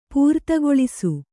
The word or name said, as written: ಪೂರ್ತಿಗೊಳಿಸು